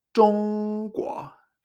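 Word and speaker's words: Zhongguo (中国): La combinación 1-2 es el primer tono, chong, y el segundo, me extraño. La palabra clave va a ser China, que es Zhongguo. Fíjate. Mm, mm. Primer tono mm, y luego me extraño. Zhongguo.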